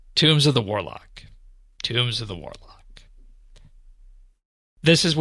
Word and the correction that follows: tomes: Tombs or tomes? – Tomes. – Tomes, not tombs? – Okay.